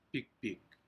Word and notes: pik pik: Aneityum

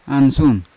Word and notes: ansun: Yawa

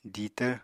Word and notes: ditvr: apatani

